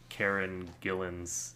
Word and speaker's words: Karen Gillan: And maybe it's just Karen Gillan's...